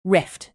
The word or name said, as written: rift